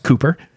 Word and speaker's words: Cowper: It looks like Cowper, C-O-W-P-E-R, but trust me, it's pronounced Cooper.